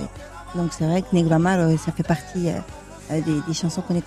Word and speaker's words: Negramaro: Negramaro. – Negramaro, ah, oui. Negramaro.